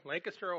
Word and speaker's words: Lancaster: and that's how they pronounce it in Ohio, Lancaster, Ohio.